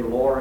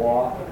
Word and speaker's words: law: You hear of the Kennedys particularly say lore and order. With that R in there. That R is simply there because it's a sloppy way of saying law and order.